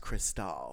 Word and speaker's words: Cristal: Crystal Connors named herself after a champagne that's pronounced Cristal. – Yes. I noticed that. Like, I kind of noticed that in passing is when she said that. I was like, oh, that must be a reference to Cristal, which that's really interesting.